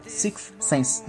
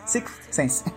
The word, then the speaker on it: Sixth Sense: Six Sense é a música. – e eu de pronúncia difícil falar Sixth Sense. Sixth Sense.